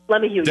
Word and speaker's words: LeMahieu: And then Devin, is it LeMahieu? Is that how you say it? LeMahieu? – LeMahieu, yep.